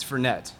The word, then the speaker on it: Fernet: on, you pronounce it Fernie Branca or Fernet? – It's Fernet.